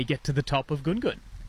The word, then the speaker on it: Ngungun: I'm at the base of the mountain that's spelt N-G-U-N-G-U-N. We often call it Gun Gun. You've heard Nunu before, but I've got it on good authority that potentially this third one, which is Gun Gun, is the correct pronunciation.